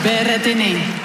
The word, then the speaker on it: Berrettini: Berrettini. – Eh bien, elle l'a bien prononcé.